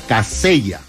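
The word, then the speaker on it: Kaseya: Se pronuncia Casella.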